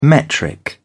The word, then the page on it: metric: British English